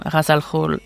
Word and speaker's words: Ra's al Ghul: And then for the G-H-U-L, I used Forvo to find the G-H. And the G-H is a throat sound in Arabic, so it would be Khul.